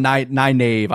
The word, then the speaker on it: Nynaeve: No, but I'm Nynaeve. I spell it just Nynaeve in my head. Yeah, like like Wednesday. That's the way I think of it.